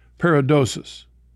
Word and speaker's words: paradosis: But the word tradition in the New Testament comes from a Greek word, and make sure I'm saying this right, Monsignor, paradosis. – Right. – Is that right? – Tradition, paradosis. Uh-huh.